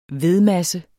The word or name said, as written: vedmasse